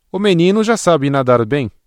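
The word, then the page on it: nadar: Brazilian Portuguese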